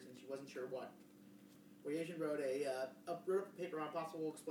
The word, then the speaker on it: Boyajian: Boyajian? Lady boys? – No, it's Boyajian.